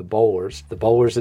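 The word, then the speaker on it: Boulware: So the Bowlers it is, but spelled like Bullware. It looks like Bullware.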